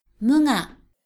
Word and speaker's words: 無我: 对我执的否定称作无我，日语念作 mu ga。